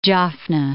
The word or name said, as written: Jaffna